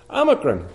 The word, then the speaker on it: Omicron: Omicron. Break it down. It's talking about O. Micron. So that's the little O. It's the short O.